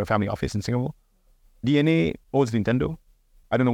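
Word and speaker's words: DeNA: It's written as Dina, but it's pronounced D-N-A, which is a huge public listed entity out of Japan.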